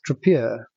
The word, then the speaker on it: Tripeur: They've acquired, and I'm not sure I can pronounce this, Tripeur.